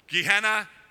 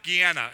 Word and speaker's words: Gehenna: It's referred to a place, it's pronounced two different ways. A lot of people, and it's like North Braddock or North Braddock. Gehenna, Gehenna.